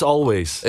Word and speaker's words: Alvvays: Yeah, it's Alvvays.